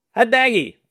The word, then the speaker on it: Hadnagy: Hadnagy.